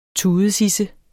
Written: tudesidse

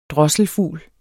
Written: drosselfugl

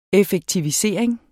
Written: effektivisering

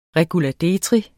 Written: REGULADETRI

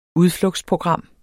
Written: udflugtsprogram

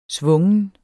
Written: svungen

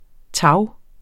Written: tage

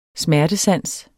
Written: smertesans

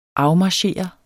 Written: afmarchere

